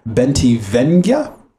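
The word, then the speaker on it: Bentivegna: Um, but this is directed by by uh, Ridley Scott, um, which is awesome, um, and written by Robert, Roberto Bentivin. Oh Jesus, Bentivenga. I believe that's how you pronounce that.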